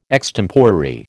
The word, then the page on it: extemporary: American English